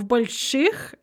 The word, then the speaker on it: больших: For soft stem, velar stem, and husher stem adjectives, you're going to add the ending ich with a soft e. В больших, and it's going to be pronounced more like an ы because sh is always hard.